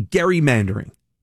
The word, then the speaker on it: gerrymandering: It's gerrymandering. People say gerrymandering. It's named after Elbridge Gerry. So it should be gerrymandering.